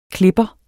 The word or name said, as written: klipper